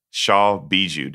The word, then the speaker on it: Shaw Bijou: There's an episode that's been well documented and that you write about in your book, was your venture, Shaw Bijou. Did I pronounce that right? – Yeah, you did.